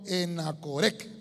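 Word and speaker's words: Enhacoré: Enacorequec, perdón, Enacorec, así es, con acento en la E, Enacorec.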